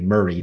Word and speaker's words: Maury: And for those who want to Zillow it, because it is such an amazing place, Maury is how you say Murray. Don't look for Murray like the Odd Couple. It would be Maury.